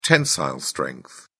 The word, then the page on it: tensile strength: British English